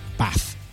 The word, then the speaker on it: Bath: And by the way, sorry to be pedantic, it's pronounced Bath.